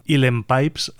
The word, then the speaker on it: Uilleann: Ese instrumento que en irlandés se suele llamar Uilleann Pipes, aunque se escribe un poco más complicado, Uilleann Pipes, y que él maneja, pues como habéis visto, a las mil maravillas.